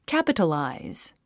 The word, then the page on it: capitalize: kǽpət ə làiz; キャピタライズ